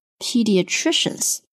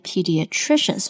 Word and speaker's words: pediatricians: 那 leading 后面的这个名词非常的长啊，这个词念作 pediatricians, OK, pediatricians, 我们注意重音在第三个音节上面， pediatricians。那看到 IAN 结尾的词呢，我们都知道它一定是某种人啦，比如说 physician 就是医生， musician 是音乐家， pediatricians 它是一种专门的医生，就是儿科医生。